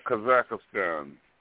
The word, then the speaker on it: Kazakhstan: Kazakhstan, am I saying that right? – Kazakhstan. – Kazakhstan, okay. Yeah, I'm looking at it here.